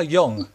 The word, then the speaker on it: Jung: How do you pronounce Carl Jung? – Jung.